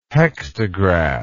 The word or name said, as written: hektograph